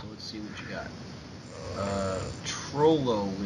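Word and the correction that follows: Trololo: lyrics. Am I saying that right? – I think it's Trollolo.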